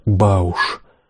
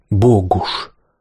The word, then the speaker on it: Богуш: Я расслышал его как «Бауш», но, поразмыслив, решил, что, пожалуй, правильнее будет все-таки «Богуш». Так произносил его, кстати, и мой новый сосед. Больные в палате с течением времени менялись. Вот и ко мне, на второй ярус моей койки, Петька, после того, как еще к вечеру первого дня унес оттуда кого-то, вскоре положил новичка, парня моего возраста и, как потом выяснилось, тоже еврея, но по языку поляка, чье имя Петька и Избышек произносили как Кухальский или Кухарский, всегда именно так, нажимая на «харский».